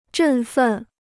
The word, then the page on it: 振奋: zhèn fèn